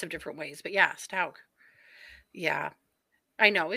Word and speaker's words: Stauch: Also, we've been saying her last name wrong this whole time. It's Stauch. – Stauch, yeah.